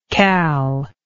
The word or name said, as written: cal